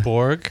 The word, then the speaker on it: Borg: But that becomes the Borg, right? Am I saying that correctly? – Yeah. – The Borg? – Yes.